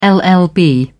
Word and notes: llb: British English

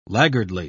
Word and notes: laggardly: British English

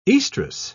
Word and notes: oestrus: British English

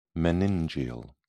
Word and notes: meningeal: British English

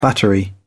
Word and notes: battery: British English

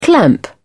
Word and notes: clamp: British English

